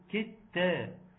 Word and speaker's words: كدت: اختنا سمر بتقول مش فاهم السؤال، كلمه كدت فيها كاف ودال وتاء، بس انا ما بنطقش الدال، ما بقولش ايه ان كده كدت كدت، انا بقول ايه كدت تا.